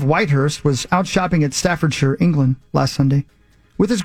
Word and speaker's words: Staffordshire: Rather than Staffordshire. – Staffordshire? – No, Staffordshire. – Staffordshire? – He said it properly. – He said, you did a good job. – Thank you. – Instead of saying it like most Americans.